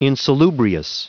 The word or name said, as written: insalubrious